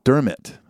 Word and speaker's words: Dermot: Just to clarify, his name is the name Dermot is quite common in Ireland, and it's pronounced Dermot. Okay, like a catcher's mitt. Dermot, there it is.